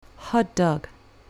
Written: hot dog

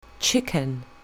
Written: chicken

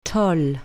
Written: tall

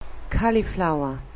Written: cauliflower

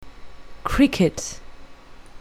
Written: cricket

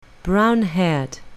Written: brown-haired

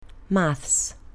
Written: maths

